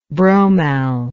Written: bromal

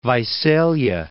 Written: Visalia

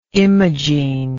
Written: imogene

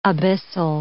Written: abyssal

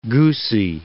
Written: goosey

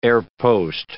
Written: airpost

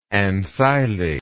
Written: ancile

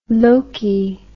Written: lokey

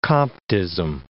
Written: Comtism

